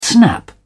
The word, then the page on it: snap back: British English